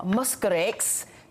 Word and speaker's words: Mosquirix: It is called RTSS or Mosquirix. Dr. Faith, did I say that right? – Yes, you did.